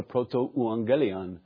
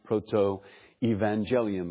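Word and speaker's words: protoevangelium: So it's pronounced proto-euangelion, or sometimes it's pronounced proto-evangelium, because the you flip into a V in English.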